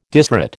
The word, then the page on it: disrate: American English